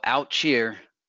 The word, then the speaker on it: Outchea: There's a song called Out Cheer. It's spelled O-U-T-C-H-E-A.